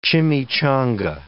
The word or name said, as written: chimichanga